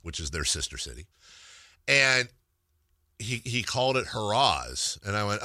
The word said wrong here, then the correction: Harrah's: oh, yeah, no, it's spelled like that. It's actually Harris.